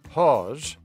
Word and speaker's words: HAWS: A director for the Humane Animal Welfare Society, that's HAWS for short, as in rhymes with paws,